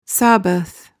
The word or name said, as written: sabbath